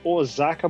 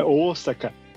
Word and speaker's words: Osaka: Mas toda vez que alguém fala Osaka, morre um japonês. – Por quê? Como se fala? – É Osaka. – Osaka. – É Osaka. – Ah, eu não sabia. – É normal, normal. – E o O tem que ser, tipo, você falar com grandeza, porque é o O de grande mesmo, e a leitura são dois O.